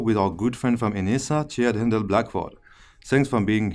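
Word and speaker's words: Enhesa: Yeah, it is Enisa. That is the official pronunciation of the company name.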